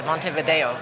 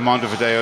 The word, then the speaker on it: Montevideo: So the V in Montevideo is pronounced B, though.